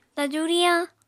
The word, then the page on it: taju-riyañ: apatani